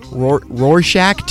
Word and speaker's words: Rorschach: I never know how to say this word right. Rorschach tests? – Yes. – Is that how you say it? – Okay.